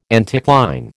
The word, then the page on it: anticline: American English